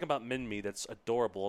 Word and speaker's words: Minmi: I know it's probably pronounced Minmi with a long I, but I like Minmi because it sounds like mini, and that's what this is, a mini ankylosaurid.